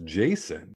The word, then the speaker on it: Jason: And my name is Jason, pronounced like it sounds, with a high J and an asin at the end of it.